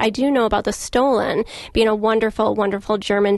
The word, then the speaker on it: Stollen: My grandfather pronounced it Stollen. – Yes, very good.